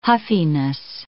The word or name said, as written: huffiness